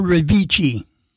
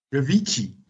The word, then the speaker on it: Revici: How do you pronounce his name? Vinci? – Dr. Revici. – Revici.